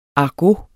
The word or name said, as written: argot